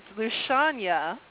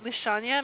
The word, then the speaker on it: Lushanya: It's Lushanya. You said the first name right, Lushanya.